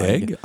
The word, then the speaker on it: Eig: I just finished a fantastic biography of Martin Luther King by Jonathan Egg, E I G. I'm not sure how you say his name. Maybe it's Eig. – Egg. – Egg. Egg. Okay, there you go.